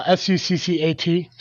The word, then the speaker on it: Succat: His birth name was Maewyn Suckett. – Oh. – I'm pretty sure I spelled, said that wrong, but Suckett sounds better than this. Uh, S U C C A T, Succat.